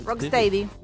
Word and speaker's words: Rock Steady: Este disco Rock Steady, ¿lo dije bien? – Rock Steady. Steady.